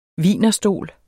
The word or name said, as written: WIENERSTOL